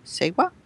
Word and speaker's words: seva: How do you say that word? Seva? – Seva. Yeah, exactly. S-E-V-A. – S-E-V-A.